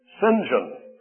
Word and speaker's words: St. John: Incidentally, this Bible teacher's name was St. John, but like the English, they love to pronounce English in ways that we English-speaking people don't quite comprehend. Mr. St. John pronounced his name St. John.